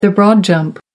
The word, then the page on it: broad jump: American English